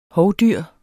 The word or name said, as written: hovdyr